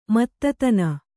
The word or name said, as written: ಮತ್ತತನ